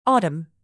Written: autumn

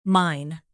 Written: mine hole

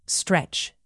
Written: stretch

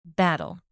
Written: battle

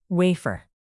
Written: wafer

